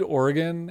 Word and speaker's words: Oregon: I reviewed Oregon and am I saying it right? I feel like there's multiple ways to say it. Nope, that's the right one.